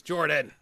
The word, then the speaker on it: Jordan: My name is Jordan, with a silent PH in the middle, and I'm joined always by my wonderful co-host, Sir Lucian, over at Sir Lucian Gaming.